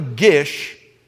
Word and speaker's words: Gish: But can I tell you that there's a doctor named Dwayne, and I think it's pronounced Gist, G-I-S-T. And Dr. Gish, G-I-S-H, not T, G-I-S-H, he documented 280 cultures that were ancient.